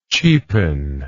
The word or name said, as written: cheapener